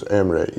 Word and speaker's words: Emre: And my first name is Emre, E-M-R-E.